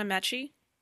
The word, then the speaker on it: Ameche: I wonder, so the voice of Shadow, and I might say his name wrong, but it's Don Ameche. Is that how you pronounce his last name? – As a family member of his, I would say yes.